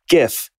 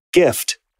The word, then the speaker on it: GIF: The correct way to say it is GIF with, like, gift. With a hard G, not GIF with a soft G, like gin.